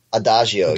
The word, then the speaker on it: Adagio: And that's Adagio. Am I saying it correctly? – Oh, Adagio. Yes.